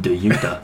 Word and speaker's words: Dejounte: DeJounte Murray. – DeJounte. I didn't know if it was hard or it. – Soft J.